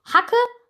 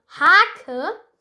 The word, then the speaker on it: Hake: Man kann, wenn man mit einer Hake, nicht mit einer Spitzhake, sondern mit einer Hake, achtet auf die Betonung, nicht Hacke, sondern Hake, wenn man mit einer Hake auf den Boden klickt, kommt daraus Ackerboden.